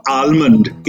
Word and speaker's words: almond: For example, it's not almond, it's almond. The L is silent.